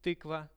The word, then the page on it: tykva: Russian